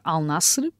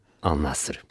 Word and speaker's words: Al-Nassr: Al Nasser, Al Nasser, como é que será a melhor pronúncia? – Diria que a partida será Al Nasser, pelo menos é isso que temos eh, ouvido mais também por parte eh, dos sauditas. Al Nasser.